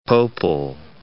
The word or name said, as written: Pople